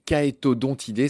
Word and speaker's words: Chaetodontidés: Le nom de leur famille, c'est les Caetodontidés. Ça s'écrit Chaetodontidés.